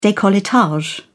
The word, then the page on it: decolletage: British English